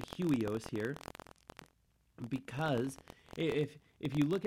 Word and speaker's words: huios: Well, if you go back to the Greek word used here, the Greek word, and I might say it wrong, but it's huios, huios, or H-U-I-O-S, huios.